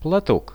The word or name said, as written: platok